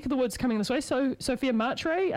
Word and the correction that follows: McRae: I think, is heading up to play. – Yes, Sophia McRae, sorry, wow. There we go, thank you, that's how you say it.